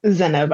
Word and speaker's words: Zeynep: Zeynep, I believe is how you say it. Yes.